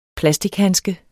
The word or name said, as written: plastichandske